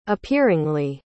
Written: appearingly

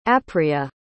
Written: apria

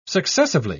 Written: successively

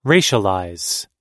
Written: racialize

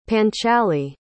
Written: panchali

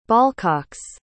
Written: ballcocks

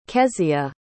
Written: kezia

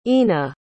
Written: ena

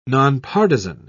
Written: nonpartisan

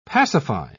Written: pacify

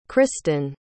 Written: Krysten

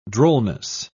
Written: drollness

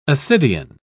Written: ascidian

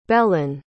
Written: belen

